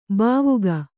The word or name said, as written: ಭಾವುಕ